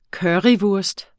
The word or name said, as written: currywurst